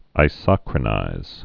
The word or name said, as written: isochronizing